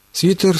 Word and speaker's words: свитер: Главное, никакого смягчения «т» в этом слове нет. «Свитер». Все.